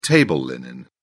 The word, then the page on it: table linen: British English